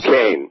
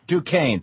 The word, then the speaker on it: Duquesne: He's a professor at, how do you pronounce this, the university name? – Duquesne. – Duquesne, thank you so much.